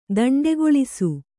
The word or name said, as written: ದಂಡೆಗೊಳಿಸು